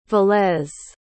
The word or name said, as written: velez